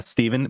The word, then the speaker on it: Stefan: My dad's name is Steven, but it's spelled Stefan.